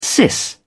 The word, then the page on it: sis: British English